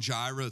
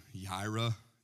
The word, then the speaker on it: Yireh: I know my Hebrew pronunciation isn't that good, but the song Jireh, it's really Yireh, Yahweh.